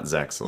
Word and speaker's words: Zexal: It's Zale, not Zexal, right? – Yeah, Zale or Zexal. I'm not too stingy with it. Some people are, but either one works, yeah.